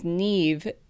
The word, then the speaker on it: Niamh: Just to give you an example of how difficult it is to pronounce these things, Niamh is pronounced Niamh, but spelled N-I-A-M-H. Not very intuitive, so.